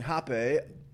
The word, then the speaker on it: rapé: When I began going to ayahuasca ceremonies, I noticed that in addition to the uh, ayahuasca, the shaman was offering rapé. Uh, for those of you uh, wanting to do your own research on this, it's spelt like rape with a little accent over E.